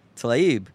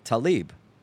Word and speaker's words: Tlaib: It's, in other words, obviously an American is not going to pronounce it with the proper accent, but it would be closer to say Tlaib than Talib.